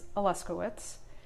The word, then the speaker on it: Aluskewicz: It's Aluskewicz.